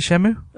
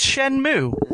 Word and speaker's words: Shenmue: It's pronounced Shenmue.